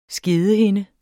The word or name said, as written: skedehinde